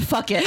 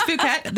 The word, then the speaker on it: Phuket: It is actually not fuck it. – Oh, no, I was like, that's not how Phuket. – I was like, wait, is Phuket the right way to say it? – Yes.